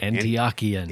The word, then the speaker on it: Antiochian: On the other end of just uniqueness, I've really enjoyed connecting with Father Peter Kavanaugh, who's at the local Antiochian, am I saying that right? Orthodox Church. Antiochian. Yeah, that's right. – Antiochian.